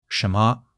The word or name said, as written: shen2 me5